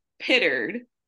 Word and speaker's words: Pitteard: We are back with a great episode, and I am so grateful to God to have Mrs. Brittany Pitteard.